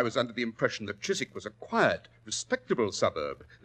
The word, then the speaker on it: Chiswick: The Chiswick branch, to be exact. – Chiswick? – It was originally pronounced Chiswick, Watson, and it's still so spelled. It means cheese farm, I believe.